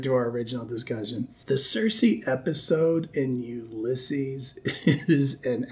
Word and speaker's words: Circe: So every English dictionary you can find, OED, Oxford English Dictionary included, suggests Circe is the proper pronunciation.